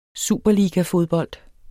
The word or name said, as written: superligafodbold